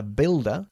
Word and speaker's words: builder: A builder. – Un albañil. – Bien. Tenemos que olvidarnos de la presencia de la U ahí. Builder.